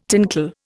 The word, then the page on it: tinkle: Indian